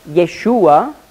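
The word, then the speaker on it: Yeshua (ישוע): Die griechische Form des Namens Josua, hebräisch ausgesprochen Jehoshua, oder Kurzform Yeshua, ist Jesus.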